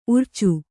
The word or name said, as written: ಊರಿಚ